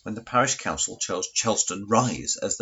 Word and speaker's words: Chelveston: Chelston is indeed the correct traditional pronunciation for the settlement of Chelveston.